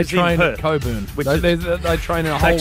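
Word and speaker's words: Cockburn: I don't care how you spell it. It's pronounced Coburn. – How do you spell it? That's a question. – No. – C-O-C-K.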